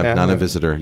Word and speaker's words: Nana Visitor: Am I saying that right? – Yes. Yeah. Nana Visitor.